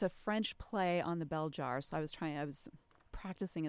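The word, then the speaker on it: Belzhar: So I'm going to give you a chance to introduce, am I saying it right, Beljar? I know it's... – I say Beljar. Okay. A little stress on the first syllable more, but it's fine.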